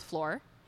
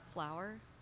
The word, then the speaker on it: Flor: So the story I'm telling is of two girls, they're twin sisters, and one of them is Flor, F-L-O-R, so hopefully I'm saying that right. – Okay. – I don't know how else you pronounce that, so I'm going to go with Flor. And Flower? – Flor.